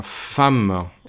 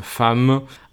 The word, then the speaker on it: femme: Y la palabra siguiente, mujer, se pronuncia femme. Y no le busquen una explicación porque es femme, apréndanselo así y ya está.